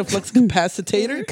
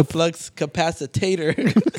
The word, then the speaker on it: flux capacitor: Repeat right there, that, just so I never say that wrong again. – Flex capacitor. – Capacitor. – Capacitor. – Not a flux capacitator. – Got it. Flux capacitor.